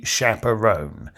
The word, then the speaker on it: chaperone: On my good name, there will be doubt cast with never a sign of any chaperone." Chaperone rhymes with alone.